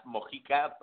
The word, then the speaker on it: Mojica: it's pronounced Mojica, but it's fine.